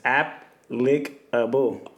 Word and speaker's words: applicable: Yeah, but it's app-lick-able. – Right. – Like the apple is lick-able.